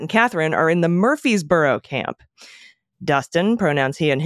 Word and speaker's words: Murfreesboro: And it's all of our corrections for Murfreesboro. – Great. I get to say Murfreesboro.